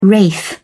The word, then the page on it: wraith: British English